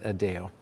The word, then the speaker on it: Adão: And I say it a little bit funny because in Portuguese, that's pronounced Adão, which is the Portuguese word for Adam, but he pronounces it Adão.